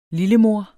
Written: lillemor

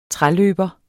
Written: træløber

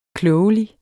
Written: KLOGELIGT

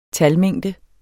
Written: talmængde

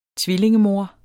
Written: tvillingemor

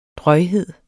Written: drøjhed

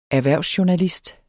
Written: erhvervsjournalist

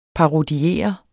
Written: parodiere

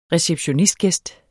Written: receptionsgæst

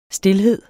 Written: stilhed

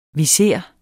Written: viser

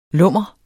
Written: lummer

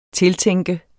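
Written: TILTÆNKES

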